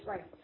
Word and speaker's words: Bryce: Bryce. – Bryce. Say it right. Bryce. B-R-Y-C-E.